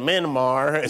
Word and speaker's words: Myanmar: Yeah, why what happened in Myanmar is pronounced like minibar.